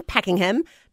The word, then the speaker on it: Pakenham: And waking up with us this morning is the lovely Tara from Pakenham.